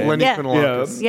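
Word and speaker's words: Eleni Kounalakis: Eleni Kunalakis.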